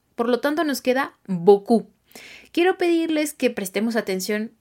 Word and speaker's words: beaucoup: Decimos boku, boku. Se escribe b e a u c o u p.